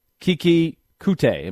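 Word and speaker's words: Keke Coutee: You've got Kiki Kute. Am I saying that right? – Yes.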